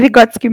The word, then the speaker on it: Vygotsky: Tô falando essas coisas e trazendo Freud também me fez lembrar do Vygotsky. Eu não sei se pronuncia exatamente assim, mas enfim. Tá certo. Era. – Oi? É Vygotsky mesmo.